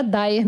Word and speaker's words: Dyer: O nome da Gabriele era Dyer mesmo. D-Y-E-R. Mas como a pronúncia era Dyer, quase toda a imprensa brasileira passou a escrever D-A-Y-E-R.